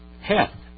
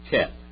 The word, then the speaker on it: Heth: Heth. All right. Now, there's a different pronunciation for this in modern Hebrew. Cheth. Cheth. C-H. All right. Instead of Heth.